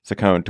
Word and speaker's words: Saccone: Is that how you pronounce his name, Saccone? – Mm-hmm. – I've never heard it. I've just read it. Saccone, to win the special election for a House seat in southwestern Pennsylvania.